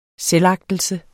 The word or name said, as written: selvagtelse